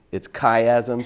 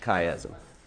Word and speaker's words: chiasm: But yeah, it's chiasm. So C-H-I, the Greek letter for X, ism. So chiasm. So anyway, the... – Say that again. – Chiasm. – C-H-I-S-M. – I'm sorry. I'm saying that wrong, aren't I? – Like Chisholm Trail, an A in there. – Yeah, there is an A in there. – C-H-I-A-S-M. – There we go.